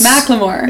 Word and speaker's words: Macklemore: And I'd say Macklemore, like a lot of people. – No, know. It's so wrong. It's Macklemore.